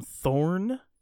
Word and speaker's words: Thorne: Abraham Thorne rhymes with corn.